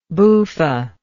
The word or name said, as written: buffa